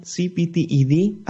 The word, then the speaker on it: CPTED: Ya estamos de vuelta en Ciencia o Nada y me encuentro en compañía de Macarena Rau, presidenta de la International CPTED Association.